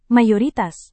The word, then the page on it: mayoritas: Indonesian